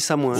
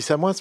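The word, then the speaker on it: Samoëns: Alors, il paraît qu'on ne prononce pas le S. Non, il paraît qu'on ne dit pas le S. – J'ai habité en Haute-Savoie 8 ans, enfant, mon cher ami. – C'est marqué sur Wikipédia, on ne prononce pas le S. – Non, on dit Samoin.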